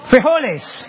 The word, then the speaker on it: frijoles: There's just frijoli. Frijoli, right? What's frijoli? Wait, quiet. Frijoles. Okay. There's no frijoles. No, there's loads of frijoles. There's mucho frijoles. There's machismo frijoles.